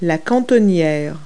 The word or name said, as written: valance